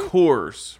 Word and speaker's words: Coors: How do you pronounce it again? – Coors. Rhymes with doors.